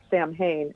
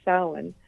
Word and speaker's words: Samhain: But and in the movie Halloween, the John Carpenter movie, they pronounce it Samhain as if it's like a person named Sam. But yeah, because it's Celtic, it's got a weird pronunciation. So yeah, it is actually pronounced Samhain.